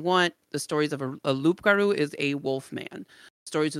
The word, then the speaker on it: loup-garou: Loup-garou, if you're going to say it and you're like, not Cajun, you say loup-garou is how it looks like it's spelled.